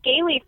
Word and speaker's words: Gailey: I'm at Gailey Frey, and that's spelled GAILEYFREY, as in Gallifrey from Doctor Who, but Gailey, which is my last name.